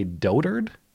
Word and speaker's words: dotard: But But Kim Jong-un gets back and writes this letter in which he calls Trump a dotard. D-O-T-A-R-D. I had to look up how to pronounce that. Apparently it's dotard. I like dotard. Trump is a dotard, like a doughboy, but a dotard.